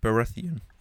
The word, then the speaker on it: Baratheon: Ja, Baratheon.